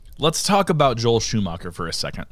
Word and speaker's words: Schumacher: This, how do you pronounce his last name? Joel? – Schumacher. – Shoemaker? – Schumacher.